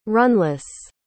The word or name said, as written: runless